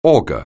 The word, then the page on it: augur: anglais